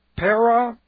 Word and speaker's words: peripateo: It is a present active indicative of peripateo. Now, this is P-E-R-I. This is a prepositional prefix, kind of like around, para, and then pateo. P-A-T-E long O. Peripateo.